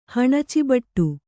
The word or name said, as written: ಹಣಚಿಬಟ್ಟು